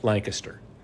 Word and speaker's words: Lancaster: And on a similar note, the county seat is Lanc- is spelled like Lancaster, but it's pronounced Lancaster.